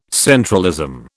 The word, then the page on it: centralism: British English